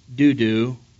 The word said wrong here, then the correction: Dodo: No, actually, it's pronounced Dodo, and I apologize, that's the second straight message where I said Dudu.